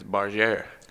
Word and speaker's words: Bargère: And it's your new last name is Bargère. – Yeah, Barger. Yeah, it's French pronunciation.